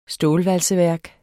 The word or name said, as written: stålvalseværk